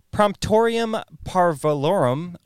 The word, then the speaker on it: Promptorium Parvulorum: It also showed up in 1440 in, I'm going to totally butcher this, Promptorium Parvulorum, which is a bilingual English and Latin dictionary attributed to Geoffrey the Grammarian. Yeah, nailed it.